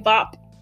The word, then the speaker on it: Vop: Vop as in like V-O-P, not V-O-B.